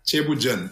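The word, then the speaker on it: Ceebu jën: No sé si lo he pronunciado bien. Chobo diane. – No lo has pronunciado. – Me lo ha dicho un... – No lo que querías. – ¿Cómo es la pronunciación? – Chebujen. – Ah, ¿ves? Bueno, casi.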